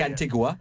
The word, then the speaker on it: Antigua: It's Antigua. I know it looks like Antigua, yeah. But it's actually pronounced Antigua. Congratulations.